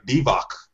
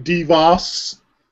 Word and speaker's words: Divac: I'm glad you pronounced that correctly. – Because no one else has through history. – No one else in sports history has pronounced his name. – Oh, gosh. Are we talking about Bob Costas now? – Not even Bob Costas said his name correctly. – Then I might like Bob Costas if he didn't say his name correctly. – I've heard Vlade DeVos.